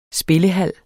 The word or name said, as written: spillehal